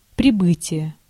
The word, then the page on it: прибытие: /prʲɪˈbɨtʲɪjə/; pribytije